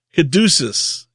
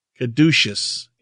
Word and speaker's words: Caduceus: Caduceus, yes, as the emblem of Kali's power. Caduceus, yes, that's it. I haven't seen that spelled out for a while, so sorry about that. So the caduceus is the emblem of Kali's power, the emblem of witchcraft or occult science.